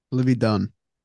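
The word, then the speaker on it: Livvy Dunne: So the internet thinks, and I don't know how to say her name. I believe it's Libby Dunn. – Libby Dunn.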